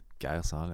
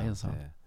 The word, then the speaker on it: Geir Zahl: Er det udtalt korrekt? Geir Sal, ja. ja, ja, Geir Sal.